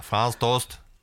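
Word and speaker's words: Franz Tost: Ja, så ska det uttalas, ja. Franz Toast.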